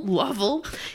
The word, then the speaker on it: Lovell: Lovell. Yeah, it's like... – It rhymes with shovel. Shirley Lovell, shovel.